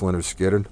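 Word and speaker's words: Lynyrd Skynyrd: 1973, Leonard Skinner's first album, Pronounced Leonard Skinner, my favorite, always been my favorite.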